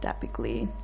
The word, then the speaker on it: Datblygu: But it's spelled Datblygu. But it said Datblygu.